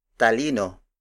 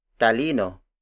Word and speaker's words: talino: For example, talino, talino, talino. Talino is spelled as T-A-L-I-N-O.